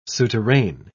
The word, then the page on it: souterrain: British English